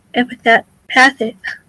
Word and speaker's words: empathic: How do you say it? Empathic. – Empathic, yeah.